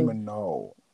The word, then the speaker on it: Minogue: I thought it was Kylie Minogue. – No, she's from Australia, not Italy. So it's Minogue.